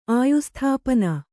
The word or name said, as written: ಆಯುಸ್ಥಾಪನ